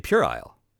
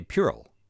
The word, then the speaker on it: puerile: I will also say, as a quick uh, side note, my family still teases me with mispronouncing puerile after our other conversation so many moons ago. How, how did you pronounce it? – I say puerile. You and your phone say puerile.